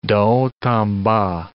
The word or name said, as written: de haut en bas